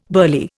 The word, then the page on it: burly: Indian